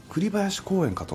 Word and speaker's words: 栗林公園: 栗林公園かと思いましたら、